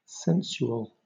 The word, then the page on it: sensual: British English